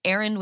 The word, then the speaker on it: Erin: I also have some problems with the fact that it rhymes with Aaron. So when I give my name places now, I have to be like Aaron with an E so that people aren't like, there's a Karen here.